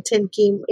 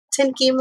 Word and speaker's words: Thien-Kim: And it's actually fun to hear you actually to say my name, Thien-Kim, in Vietnamese instead of the version I give everyone else. So I'm Thien-Kim Lim, and I like to talk about sex, toys, and pleasure.